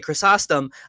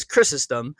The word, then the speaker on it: Chrysostom: How do you pronounce his name? – Either is completely acceptable. I grew up grew up academically just saying Chrysostom. So I'm going to say Chrysostom all the way through, but I've noticed that Brits and Australians tend to go with Chrysostom, and Americans tend to do Chrysostom.